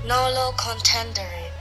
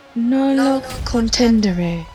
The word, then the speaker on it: nolo contendere: How do you say Google? – Nolo contendere. – Oh, I said it right. – Yeah, you did. – Nolo contendere.